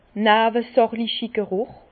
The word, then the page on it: newesächligi Gerùch: Bas-Rhin; Herrlisheim